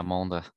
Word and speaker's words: Mende: Okay, the next important stage is stage 14, which will finish on the 9 minute climb, watts per kilo test, which is Monde. Yeah, that's how you pronounce. – Yeah, Monde.